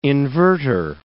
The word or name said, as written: inverter